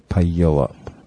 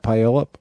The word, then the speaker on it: Puyallup: Puyallup. Puyallup. Puyallup. Puyallup. You know how to say that? Puyallup.